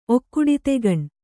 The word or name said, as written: ಒಕ್ಕುಡಿತೆಗಣ್